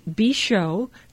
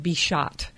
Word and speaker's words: Bichot: So this is Bichot. That's the winemaker. B-I-C-H-O-T. You could pronounce it to remember it. Bichot. B-I-C-H-O-T. Bichot. And it's a Cremant, C-R-E-M-A-N-T. Think of creamy. Rosé. R-O-S-E.